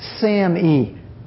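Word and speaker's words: SAMe: It's pronounced SAM-E.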